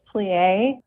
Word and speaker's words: PLIE: I think it's pronounced PLIE, Preventing Loss of Independence Through Exercise, and how exercise can help those that are living with Alzheimer's and their caregivers. – Yes, so you pronounced it correctly. It is PLIE.